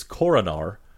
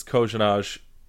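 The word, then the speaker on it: Korenar: The NHL official pronunciation sheet says it's Koronar. He says it's Kojanaj because that's how you pronounce it in his native tongue.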